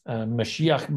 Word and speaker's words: Mashiach: Mashiach Ben Joseph is the way it's pronounced.